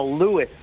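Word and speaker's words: Lewes: Also, I'm from Lewis, Delaware. – Oh, Lewis, I'm sorry. – It's a Scandinavian pronunciation, and I'm 4 miles from the plug.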